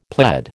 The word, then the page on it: pled: American English